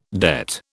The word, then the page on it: debt: British English